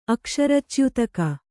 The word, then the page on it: ಅಕ್ಷರಚ್ಯುತಕ: Kannada